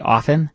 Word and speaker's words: often: I don't say it with the T. – You say often? – I do. You say often? – I think I say often, yeah. – Yeah, I do the silent O-F-T. T. – Yeah. – It's spelled that way. – Yeah. – I just do the silent T.